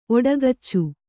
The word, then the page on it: ಔಡುಗಚ್ಚು: kannada